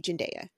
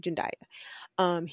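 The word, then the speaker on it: Zendaya: A good person who I would describe just visually as a dandy would be Jendaya. That's how you say his name. Jendaya.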